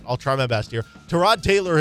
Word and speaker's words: Tyrod: Everybody says Tyrod Taylor as Tyrod Taylor, but he had said that, no, it's Tyrod Taylor.